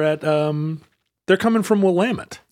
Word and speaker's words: Willamette: Oh, no, it's Willamette.